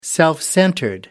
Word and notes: self-centered: British English

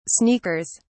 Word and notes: sneakers: British English

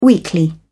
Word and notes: weekly: British English

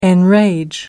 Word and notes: enrage: American English

